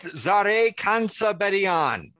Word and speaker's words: Zareh Kantzabedian: We're calling this The Children Are Our Future, Top 25 2022 NFL Draft Prospects with Zareh Kantzabedian. Did I say it right? – You guys have gotten so good at that.